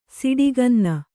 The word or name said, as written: ಸಿಡಿಗನ್ನ